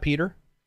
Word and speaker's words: Piotr: Can somebody tell me how to pronounce this name? P-I-O-T-R. Is that Peter? – Piotr. – There we go.